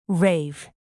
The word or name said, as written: rave